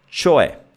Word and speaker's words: cioè: C, C. In questo caso è C più I, quindi è C o E. Cioè, in velocità. Cioè, cioè. La E finale con questo accento è come il verbo essere, no? Io sono, tu sei, lui, lei è, con questa, questo suono aperto.